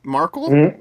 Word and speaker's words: Markle: What's her name? Megan Merkel. Is that her name? Markle? – Yes. – I always forget Markle. How to pronounce her last name.